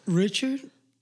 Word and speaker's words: Richard: Richard Salas is my name.